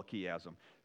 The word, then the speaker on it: chiasm: All right, it's spelled C-H, not K, C-H-I, how do I spell chiasm? C-H-I-A-S-M, so C-H is how you'd look it up online if you wanted to do that.